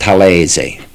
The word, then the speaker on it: Talese: The real name is pronounced Talese. T-A-L-E-S-E is Talese. And Gay is from Gaetano.